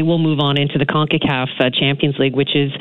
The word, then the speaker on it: CONCACAF: Am I saying that right? CONCACAF? – Yes, you are. CONCACAF.